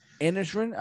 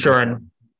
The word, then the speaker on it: Inisherin: The Banshees of Inisherin. I'm just going to Inisherin. – Inisherin, thank you.